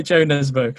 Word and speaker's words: Jo Nesbø: Yeah, and actually, to be fair to him, whenever he does an interview in English, that is how he pronounces his name. So yes, that's right, Jo Nesbø.